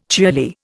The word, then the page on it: cheerly: Indian